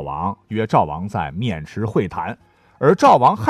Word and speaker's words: 渑池: 好，下面这个城市的名字那就更有故事了哈，叫渑池知会的渑池，绳子的绳呢是绞丝旁啊，咱换成三点水啊，不能读成绳池知会啊。